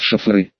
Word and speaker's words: шоферы: С некоторых пор меня перевели из курьеров в шоферы. Слово это произносилось именно так, с некоторым проносом, и обязательно через «э».